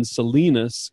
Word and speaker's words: Salinas: They have 5 grown children and live in Salinas, California.